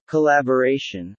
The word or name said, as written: collaboration